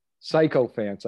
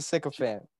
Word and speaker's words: sycophant: Yeah, psycho fans, I think she was saying. – I think it's a sycophant. – Yeah, it is. I think you're saying it wrong.